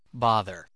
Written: bother

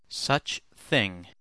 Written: such thing

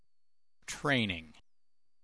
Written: training

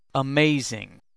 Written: amazing

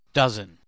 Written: dozen

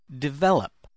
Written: develop

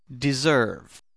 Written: deserve

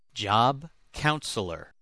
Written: job counselor

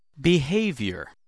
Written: behavior

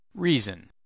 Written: reason

